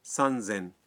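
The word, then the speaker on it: سن زین (三千): 3000 کو کہتے ہیں سن زین, اور 8000 کا تلفظ ہے ہس سین.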